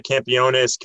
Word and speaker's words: Campeones: You won a Campeones Cup. You like that? – Nice. – I'm working on my Spanish. – Great pronunciation right there.